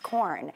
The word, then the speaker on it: Quorn: This is corn with a Q, but it's pronounced corn.